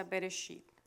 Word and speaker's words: Bereshit: La palabra el comienzo, que está en hebreo, y en el hebreo se pronuncia Bereshit.